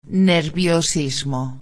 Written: nerviosismo

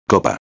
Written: copa

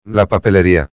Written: la papelería

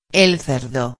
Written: el cerdo

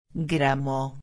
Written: gramo

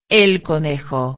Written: el conejo

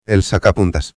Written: el sacapuntas

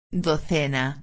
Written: docena

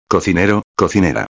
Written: cocinero-a

